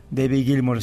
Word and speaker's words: Gilmour: el guitarrista de Pink Floyd, se escribe diferente, se pronuncia más o menos igual. David Gilmour es este guitarrista,